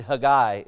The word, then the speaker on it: Haggai: Or as we say here in the South, Haggai. I don't know why we pronounce it that way. It's actually Haggai in Hebrew, but Anyway.